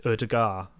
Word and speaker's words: Ødegaard: I think it's Ødegaard. I don't think I actually pronounced the D. – No D on the end.